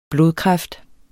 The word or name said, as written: blodkræft